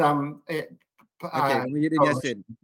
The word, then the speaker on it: Muhyiddin Yassin: Muhyiddin's, Muhyiddin Yassin, yes.